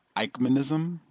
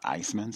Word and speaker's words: Eichmannism: For clarification, it's Eichmannism, like I-K-E, Mike and Ike. That's the way it's pronounced, not Iceman. So he's saying Eichmannism, E-I-C-H-M-A-N-N-I-S-M, Eichmannism, not ice.